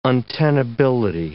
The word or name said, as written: untenability